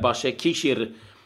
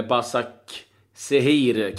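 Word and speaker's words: Başakşehir: Eh, Başakishir, eller Basak Sehir kanske man ska uttala det, men Istanbul, det är ju eh, Holmens gamla lag.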